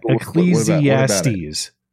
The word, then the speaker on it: Ecclesiastes: Frank, please read Ecclesiastes. Did I pronounce that right? I hope so. – Yeah. Ecclesiastes. – Ecclesiastes.